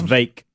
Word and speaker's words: Vivek: Vivek, is that right? Vivek, do you ever figure that out? – It's Vake. – The Vake. – Like cake. – Rhymes with cake.